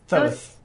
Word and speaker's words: Thoth: Thoth? – Thoth. – It's T-O-H-T-H. – It's pronounced Thoth.